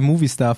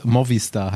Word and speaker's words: Movistar: Claudia hat uns rückgemeldet, dass wir Movistar falsch aussprechen, weil es nicht Movie wie Film ist. Und es glaube ich, wenn ich es auch diese Lautschrift nicht entziffern kann, Movistar heißen soll.